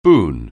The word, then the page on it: boon: anglais